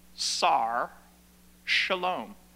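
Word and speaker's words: Sar, shalom (שר שלום): It is pronounced sar, with that little hyphen, shalom. Sar, shalom.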